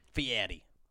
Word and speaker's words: Fieri: And that guy Fury, he came and did a little thing there. – Fieri. – Is that how you say it? – Yeah, Fieri.